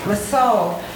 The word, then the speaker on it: Lisieux: And in a way, I'm more of a Therese of Lisieux,